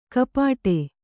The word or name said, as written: ಕಪಾಟೆ